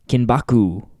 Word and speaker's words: Kinbaku: Well, tonight, we're going to discuss Daredevil, Season 2, Episode 5, Kinbaku.